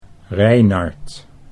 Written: Reinard

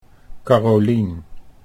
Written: Carolien

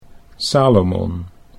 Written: Salomon

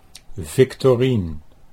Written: Victorien

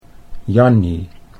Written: Jannie

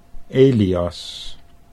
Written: Elias

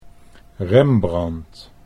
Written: Rembrand

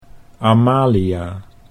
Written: Amalia

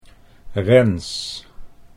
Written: Rens

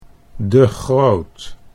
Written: De Groot

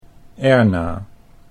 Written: Erna

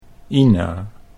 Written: Ina

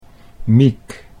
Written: Miek

